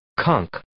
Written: conch

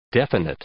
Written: definite

